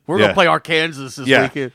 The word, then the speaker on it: Arkansas: Did you know, Walls, that it is illegal to mispronounce the word Arkansas in Arkansas. – Not, you can't say Arkansas.